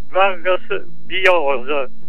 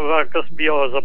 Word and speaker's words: Vargas Llosa: Degli amici peruviani mi hanno aperto gli occhi dicendo che non si pronuncia come avete fatto voi e come faccio sempre anch'io, Vargas Llosa, bensì Vargas Llosa. Cioè, in Perù loro lo chiamano Vargas Llosa, poi lascio la scelta libera, perché probabilmente in Spagna, dove lui ha la cittadinanza, dicono Llosa.